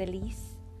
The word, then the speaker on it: Elise: My name is Elise. People butcher it all the time, so I tend to not use it as much. I don't want to be called fucking Elsie. Like, that's not it.